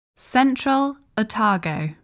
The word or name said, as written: Central Otago